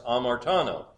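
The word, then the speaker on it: hamartanō: And I put it in your notes, starts with an H, but the H is silent, it's pronounced amartano.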